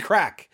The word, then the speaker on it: craic: And it's spelled in the subtitle C-R-A-I-C. That's not actually how it was originally spelled. That's an Irishification. A Gaelic. Or whatever of the word. It's actually just crack. C-R-A-C-K is the origin of the word.